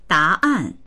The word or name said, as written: da2an4